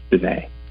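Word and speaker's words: Diné: So and it's D-I-N-E, it's like this little accent mark, you know, over the E, and it's pronounced Dene.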